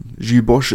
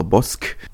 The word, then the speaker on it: Gilles Bosch: Entsprechend der halb, die halbe Startformation äh, schon aussortiert und vor dem Absprung Gilles Bosch unter anderem, beziehungsweise Gilles Bosch, äh, je nachdem, wie man es aussprechen möchte, ähm, Gilles Bosch ist korrekt, oder hat er mir persönlich so bestätigt, dass Gilles Bosch korrekt ist. Ähm, an, allerdings muss man auch sagen, dass Bosch immer noch ein, ähm, ein abwertender Begriff für sogenannte Deutsche ist. Ähm, entsprechend bevorzugen viele Franzosen die Aussprache Bosque.